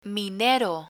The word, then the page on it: minero: Spanish